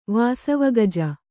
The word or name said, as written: ವಾಸವಗಜ